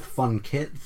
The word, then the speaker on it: Funkit: I go with Funkit, emphasis on the second syllable, so people don't think it's funk.